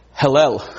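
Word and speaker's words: Hillel (הילל): That is pronounced Hillel. Sounds like hell, doesn't it?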